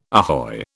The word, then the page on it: ahoy: British English